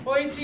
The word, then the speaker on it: Boise: It's not really that hard to pronounce. Let's make some noisy for Boise. – No. That's literally the opposite of what you had just been doing. Boise.